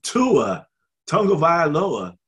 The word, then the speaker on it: Tua Tagovailoa: And Tua Tagovailoa, did I say it right? – Yes, you did.